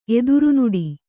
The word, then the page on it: ಎದುರ್ನೋಡು: edurnōḍu